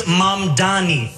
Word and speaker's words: Mamdani: The name is Mamdani, M-A-M-D-A-N-I. You should learn how to say it because we got to get it right.